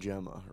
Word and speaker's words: Xhema: Alban Xhema, right.